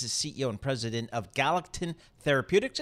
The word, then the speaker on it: Galectin: Talk to us about what you guys are working on over at Galactin Therapeutics. – Thank you for having me today. It's we pronounce it Galactin Therapeutics.